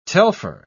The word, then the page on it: telpher: British English